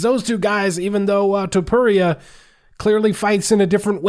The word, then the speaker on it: Topuria: Alira Topuria.